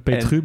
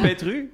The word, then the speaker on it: Petrus: Je bedoelt Petru, bedoel je? – Of Petru, dat kan ook. Ik heb geen idee. Ik hoor het voor het eerst iemand uitspreken. Is het Petru? – Zeker.